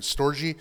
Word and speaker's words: storge: There's storge, I don't know if I'm pronouncing it right, but storge, there we go, is the affection, basically family affection.